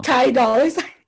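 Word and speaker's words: Ty Dolla $ign: Ty Dolla Sign.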